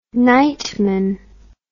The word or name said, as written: nightman